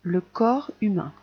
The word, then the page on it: le corps humain: French